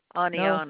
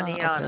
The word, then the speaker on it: Oneonta: No, Aniana. – No? Aniana. – Aniana. – Okay. Spell that for me. – O-N-E-O-N-T-A.